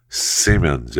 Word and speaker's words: Siemens: Uh, Siemens, Simmons, S- I, I think that's how you pronounce that right. I better learn. I better make sure I pronounce that right because I see their buildings all over when traveling. And I always, you know, think to myself, I wonder how you pronounce that exactly. Siemens. Siemens. That's what I thought. That's what I said. Siemens. Thank you.